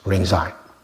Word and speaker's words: Rinzai: Another one is Ling Ji, which is pronounced in Japanese Rinzai.